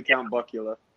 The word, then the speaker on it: Count Buckula: I mean, is it Count Buckula, like Count Chocula?